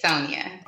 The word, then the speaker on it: Sonia: Yes, Sonia. – Okay, please introduce yourself to the listeners. – My name is Sonia B.